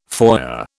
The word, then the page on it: foyer: British English